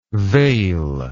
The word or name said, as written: they'll